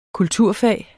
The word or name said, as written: kulturfag